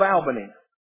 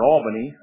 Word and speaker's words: Albany: Prince Michael of Albany. Or Albany, I guess it's pronounced.